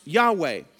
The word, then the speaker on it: Yahweh: Now, when they translated the Old Testament into Greek, there was a personal name for God that we call the Tetragrammaton, which is just four letters is what that means, all capitalized, that we pronounce Yahweh.